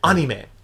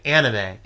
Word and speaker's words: anime: You know, in Japan, we use the ancient Japanese pronunciation, anime. – Anime. – But in English, people are more likely to say anime.